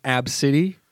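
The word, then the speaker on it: Abcde: But it's pronounced Ab City.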